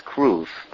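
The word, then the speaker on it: kruv (כרוב): In Hebrew, it's kruv, but in English, a cherub is how they would call it.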